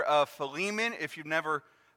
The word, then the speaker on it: Philemon: it's spelled P-H-I-L-E-M-O-N. Philemon, P-H-I-L-E-L-E-M-O-N, Philemon.